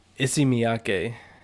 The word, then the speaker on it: Issey Miyake: Issey Miyake.